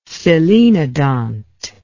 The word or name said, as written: selenodont